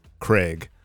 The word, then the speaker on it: Craig: Americans say Greg, essentially. And my name is not Greg, it's Craig.